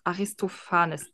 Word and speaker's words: Aristophanes: Aristophanes, PH würde ich als F aussprechen.